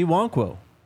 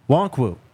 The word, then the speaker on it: Nwankwo: You changed up the pronunciation on me. – I'm on the Houston website and I see it right in front of me. – Okay. – Chidozie Nwankwo. – Chidozie Nwankwo. – Nwankwo. – Yes.